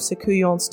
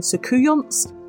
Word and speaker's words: Soucouyant: However, there is another French word, loup-garou, which comes from the word for werewolf, loup-garou, which is sometimes used to describe Soucouyants too. You'll also notice I'm taking the T sound off the end, it's just generally not pronounced with this word, so it's Soucouyant rather than Soucouyants, which I did make the mistake of doing in my announcement last week, so apologies to all my French language speaking listeners.